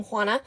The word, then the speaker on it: Juana: and we are gonna use the Spanish pronunciation for her name, Juana, because this is a different person from the Joanna we just kicked out of the story.